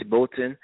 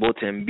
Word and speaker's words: Bolton: They can locate me at Idris Bolton. It's spelled I-D-R-I-S, last name Bolton, B-O-L-T-O-N.